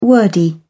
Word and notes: wordy: British English

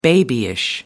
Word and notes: babyish: American English

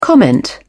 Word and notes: comment: British English

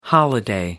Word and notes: holiday: American English

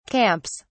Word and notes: camps: British English

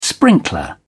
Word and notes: sprinkler: British English

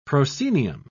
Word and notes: proscenium: British English

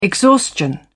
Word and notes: exhaustion: British English

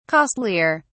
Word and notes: costlier: British English

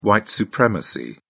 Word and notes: white supremacy: British English

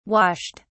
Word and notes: washed: British English

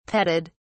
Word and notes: petted: British English

